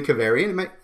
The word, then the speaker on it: Keverian: Today, we have a special guest with us, Julie Keverian.